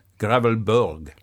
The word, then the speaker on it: Gravelbourg: There's Gravelberg. No, I mean it. In Saskatchewan, there is a tiny community called, how would you say that? It's Bourg, Gravelbourg.